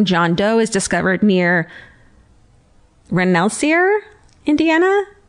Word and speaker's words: Rensselaer: Rensselaer. Rensselaer. – Spell it. – R-E-N-S-S-E-L-A-E-R.